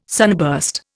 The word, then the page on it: sunburst: Indian